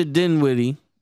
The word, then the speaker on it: Dinwiddie: They traded Spencer Dinwiddie Dinwiddie for Dinwiddie.